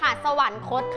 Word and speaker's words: สวรรคต: ออกเสียงใหม่ค่ะสวรรคตค่ะสวรรคตเพราะว่าคำว่าสวรรคตมีรอหันที่ทำหน้าที่เป็นออกเสียงอันสระอะกับนอหนูนะคะ